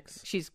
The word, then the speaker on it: Pics: It's spelled with a C, right? Not with an X. – P-I-C-S is how you spell Jane's last name, Pix. – Not with an X. – P-I-C-S.